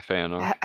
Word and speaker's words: Fëanor: Feanor? Is that how you say his name? – Yeah, Feanor.